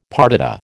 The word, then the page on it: partita: American English